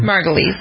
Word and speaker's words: Margulies: And maybe if you watch The Good Wife and get the whole season is built around Juliana Margulies, how do you pronounce her name? – Margulies.